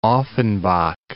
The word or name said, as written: Offenbach